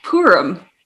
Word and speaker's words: Purim: It's Purim.